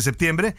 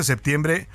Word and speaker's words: septiembre: septiembre. Y también, mire usted, en el calendario gregoriano, que es el que tenemos actualmente, septiembre o septiembre, se puede pronunciar también sin la P, es el noveno mes del año, tiene 30 días.